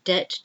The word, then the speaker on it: debt: Debt, if you owe somebody some money, you have to pay your debt to them. D E B T, silent B, debt.